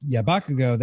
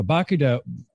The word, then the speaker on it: Barracuda: Yeah, Barracuda, there we go. Barracuda. – Barracuda. – Putting the wrong emphasis on the wrong syllable and making him a character from My Hero Academia in the process.